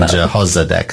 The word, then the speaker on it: Jehozadak: And Jehozadak, so the emphasis is on the O.